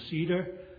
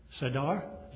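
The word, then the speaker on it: Seder: which I pronounce as a cedar, and Warren pronounce it as sadar.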